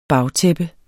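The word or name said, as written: bagtæppe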